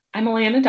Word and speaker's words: Alana: Well, I'm Alana Dawson. I always tell people it's pronounced like Atlanta without the T's.